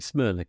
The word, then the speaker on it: Smyrna: But I suppose I should really start by saying, am I pronouncing Smyrna correctly? – I think you are.